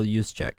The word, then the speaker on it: Juszczyk: Juszczyk? – Yeah, that's how you say it. Kyle Juszczyk.